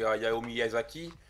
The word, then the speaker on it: Hayao Miyazaki: Non, mais après, cet artiste est connu, hein, c'est Hayao Miyazaki.